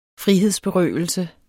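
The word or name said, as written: FRIHEDSBERØVELSE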